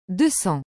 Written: 200

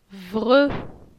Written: Vre

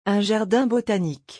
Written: un jardin botanique

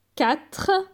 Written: 4

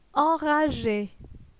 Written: Enrage

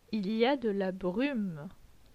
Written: Il y a de la brume

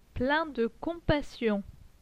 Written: plein de compassion

